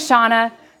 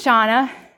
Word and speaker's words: Shawna: So my name is Shawna. I work at Awana. It's a little bit awkward because my name rhymes with my company. And so when I call people, like, hi, this is Shawna.